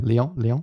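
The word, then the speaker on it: Lyon: Leon, mm-hmm. is that how you say it? – Yeah, Leon.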